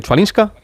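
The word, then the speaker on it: Chwalińska: Chwalinska.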